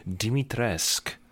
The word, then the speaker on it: Dimitrescu: It's just like how her name is Dimitrescu, silent U.